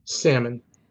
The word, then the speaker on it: salmon: How do you say the word S A L M O N. Salmon. That's right. Salmon. – Now this fucking this inbred motherfucker says the L.